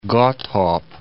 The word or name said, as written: Godthab